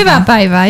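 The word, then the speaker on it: hyvää päivää: What is guten Tag in Finnish? – Hüwa Päiva. – Hüwa Päiva. – Hüwa Päiva, yeah, that's how you say it.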